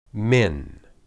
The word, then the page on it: min: Hebrew